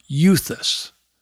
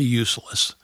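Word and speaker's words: euthus (εὐθύς): It has an abundance, a proliferation, just this huge amount of the word euthus. No, I didn't say useless. Euthus, E-U-T-H-U-S in the Greek.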